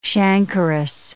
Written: chancrous

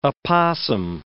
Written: opossum

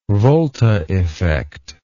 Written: volta effect

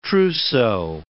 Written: trousseau

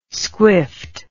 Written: squiffed

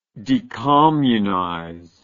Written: decommunize

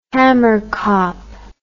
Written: hammerkop

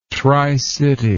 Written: tricity

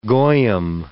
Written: goyim

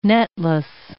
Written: netless

